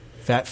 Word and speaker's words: Phat: Fat Philly? – Fat Philly. – Philly is apparently the fattest country. – Yeah, and it's spelled... – Country, city, damn it. – And I think it's spelled P-H-A-T.